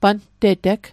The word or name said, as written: panteteg